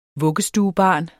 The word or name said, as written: vuggestuebarn